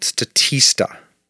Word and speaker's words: Statista: This is from a website called Statista. Statista. It rhymes with barista.